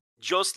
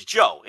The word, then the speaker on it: Jos: It's pronounced like... your name, so it's Joe. It's Joe LaDuke. Joe LaDuke. You're right. You don't say the S.